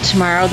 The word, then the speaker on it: Tomaro: I am your host, Deb Tomorrow. That's T-O-M-A-R-O, as my mother says.